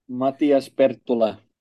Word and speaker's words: Matias Perttula: How did you say it again? Say it for us. – Matias Perttula. – Okay, what, that is an accent. What accent is that?